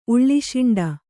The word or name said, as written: ಉಳ್ಳಿಶಿಂಡ